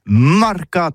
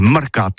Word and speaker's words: marcat: Eh bien, dis ça à tous, mais le mercat, de l'Uni ou le mercat des rebelles, mais en Gascogne toulousaine, c'est prononcé les marcat avec un A.